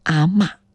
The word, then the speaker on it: 阿嬷: 跟随方言地区的发音习惯，所以呢，我们也是尊重啊，追随潮汕朋友的这个发音习惯，读成阿嬷。哎，当然有可能在下一次字典再去修订的时候，就有可能新增这个嬷字的读音了哈，大家在字典里面就能够查得到了。